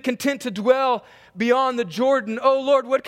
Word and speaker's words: Jordan: And by the way, that word is pronounced Jordan. Jordan. Jordan. Not Jordan.